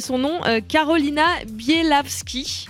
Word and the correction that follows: Bielawska: Bielawska, pardon. – Ouais, c'est un A à la fin.